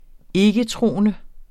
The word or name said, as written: ikketroende